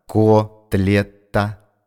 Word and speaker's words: котлета: И еще, Ванечка, поправь-ка слово «котлета». Ты написал «котлета», а нужно «котлета».